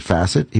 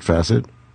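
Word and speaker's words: Facit: He calls it Facite. He pronounces it Facite.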